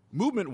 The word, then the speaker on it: MVMT: Movement watches, spelled MVMT but pronounced movement, was founded on the belief that style shouldn't break the bank.